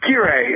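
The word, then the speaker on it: Kirei: Well, I got to say it's Kyrie. It's a Japanese word, so even I pronounce it wrong most of the time.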